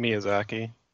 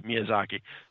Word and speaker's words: Miyazaki: Miyazaki. – Yeah, Miyazaki.